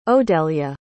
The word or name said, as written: odelya